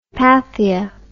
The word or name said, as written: pathia